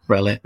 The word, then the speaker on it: Rowlett: And then on to Rowlett. Did I pronounce that right? Rowlett? – Yeah. Rowlett.